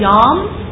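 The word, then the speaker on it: yom (יום): And the word day is pronounced yom. Y-O-W-N is the Hebrew spelling of day. It's yom.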